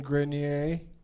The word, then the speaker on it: Grenier: Adrian Grenier. Am I pronouncing that right, Rich? – Mm-hmm. You are.